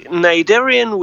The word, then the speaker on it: Cnidarian: Because okay, Cnidarian with silent C is.